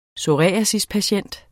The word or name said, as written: psoriasispatient